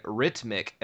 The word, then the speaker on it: Rytmik: January 12th, we get Hip Hop King Rhythmic Edition for the NES. – Rhythmic? – Yes, it's rhythmic, but it's spelled R-Y-T-M-I-K.